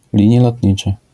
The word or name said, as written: linie lotnicze